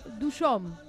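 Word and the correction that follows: Dujovne: ¿Lo dije bien? – Ah, de Duhovne.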